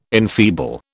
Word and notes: enfeeble: British English

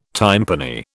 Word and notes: tympani: British English

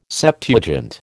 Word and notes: Septuagint: American English

